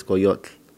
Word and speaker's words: Coyotl: Just like in Spanish, but we say in Spanish we say Coyote, and now it's Coyotl. So it's like a very silent L at the end instead of an E.